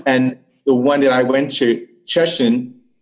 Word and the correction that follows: Shenzhen: And I think I mispronounced that Cheshire, but I still struggle saying that sometimes. – You said it's Cheshire? – S-H-E-N-Z-H-E-N. – Shenzhen. – Shenzhen. – Shenzhen, yes. Okay, got it.